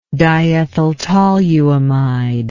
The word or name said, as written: diethyltoluamide